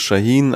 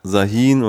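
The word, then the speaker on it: Şahin: Ja, wobei, da muss man sagen, also der Nachname von Ugo wird Shahin eigentlich ausgesprochen, aber er selber sagt Sahin und seine Frau sagt auch Sahin, weil es für die Deutschen einfacher ist.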